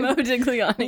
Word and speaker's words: Modigliani: Modigliani.